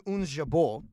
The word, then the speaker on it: Ons Jabeur: So, I'm going to go Ons Jabeur from Tunisia.